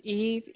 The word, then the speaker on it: Eve: First name is Eve, E-V-E. Last name is Thurman, T-H-U-R-M-A-N.